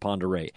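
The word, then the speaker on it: Pend Oreille: But in any event, it looks like Pend Aurelles is the way they spell it, but I think they call it Ponderay.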